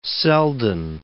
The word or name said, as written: Selden